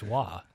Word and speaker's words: Roy: Yeah, it's spelled Roy and pronounced Waugh.